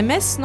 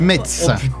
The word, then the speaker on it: Metz: Moi, je dirais Metz, non? – Exactement. – Et en plus, tu l'as bien prononcé, t'as pas dit Metz.